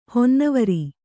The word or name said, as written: ಹೊನ್ನವರಿ